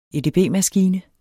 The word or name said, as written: edb-maskine